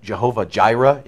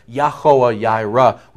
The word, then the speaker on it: Yahweh Yireh: See, we often have taken in the church that name, Jehovah Jireh, and you know Jehovah is wrong. As a pronunciation goes, Yahweh Yireh.